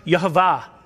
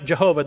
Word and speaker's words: Yehovah: It's pronounced Yehovah. It's not Jehovah. There's no J in Hebrew, but that's beside the point.